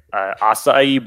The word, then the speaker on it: acai: Go get yourself an acai bowl. – I'm proud of you for pronouncing that correctly.